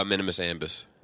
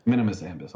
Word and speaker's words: Minimus Ambus: Oh, Minimus Ambus. – Minimus Ambus.